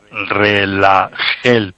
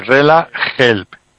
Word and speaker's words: RelaHelp: Y te podemos ayudar con un producto que se llama RelaHelp. RelaHelp. Ese help se escribe... Sí, RelaHelp. – RelaHelp. – Sí, esa help es la palabra inglesa. Se escribe H-E-L-P.